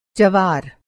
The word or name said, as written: जवार